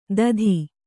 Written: ದಧಿ

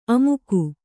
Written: ಆಮೋಘ

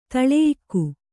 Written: ದಳೆಯಿಕ್ಕು

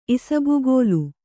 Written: ಇಸಬಗೋಲು

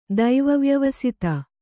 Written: ದೈವವ್ಯವಸಿತ

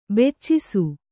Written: ಬೆಚ್ಚಿಸು

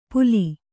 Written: ಪೂಲಿ

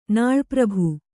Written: ನಾಳ್ಪ್ರಭು

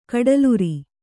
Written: ಕಡಲುರಿ

